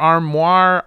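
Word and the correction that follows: Armour: I'm not sure how to pronounce it. – It's Armor.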